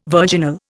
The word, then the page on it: virginal: Indian